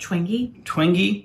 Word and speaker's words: Twenge: Twenge.